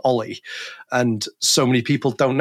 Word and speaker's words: O11Y: And I was like, yeah, it's Ollie. That's, you know, you just say Ollie.